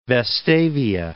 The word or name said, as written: Vestavia Hills